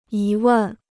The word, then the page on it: 疑问: yí wèn